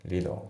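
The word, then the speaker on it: little: であと発音なんだけど、リトルのところがその T が濁るんですよね。リドル、よし、まあ、っていう感じで、 68 から 70 番ね。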